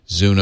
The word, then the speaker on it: Zune: Zune OS.